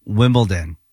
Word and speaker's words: Wimbledon: It's D-O-N. – Like Downton. – Okay, hold on. Say it correctly again. – Wimbledon.